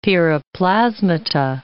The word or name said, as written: piroplasmata